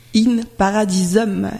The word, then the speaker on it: In Paradisum: In Paradisum.